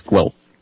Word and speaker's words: Guelph: The University of, is it Guelph? Is it pronounced like it looks? – Yes, it's Guelph.